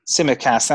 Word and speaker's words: Tsimikas: But I've got Sanchez in goal from Brighton, Maguire, Tsimikas, how do you pronounce it? Tsimikas, the Liverpool left back. – Tsimikas, that's the one.